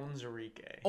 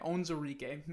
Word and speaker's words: Onwuzurike: Why am I not saying this right? – Onwuzurike. – Onwuzurike. – That's how I've heard it pronounced. – Onwuzurike. That's what it is.